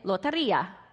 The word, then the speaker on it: lotería: And we would go and play lotería. Did I say it right? – Yes, all right.